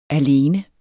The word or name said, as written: alene